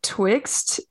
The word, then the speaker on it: Twixt: I believe it's pronounced Twixt. It's T-W-I-X-T.